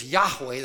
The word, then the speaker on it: Yahweh: In fact, one of the names repeated throughout the Bible for God is, the Hebrew is Yahweh.